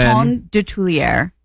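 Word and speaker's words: Coton de Tuléar: C-O-T-O-N. – Coton? – C-O-T-O-N. – Coton de Tulier. – How the hell do you spell that? – Of Tulier. D-E-T-U-L-E-A-R.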